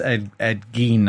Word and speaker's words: Gein: This time it's Ed Gein, I think actually is how it's pronounced.